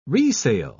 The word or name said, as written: resale